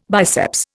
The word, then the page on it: biceps: Indian